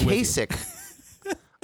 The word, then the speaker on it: Kasich: It's, it's Kasich.